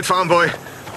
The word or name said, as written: Farmboy